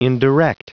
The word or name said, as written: indirect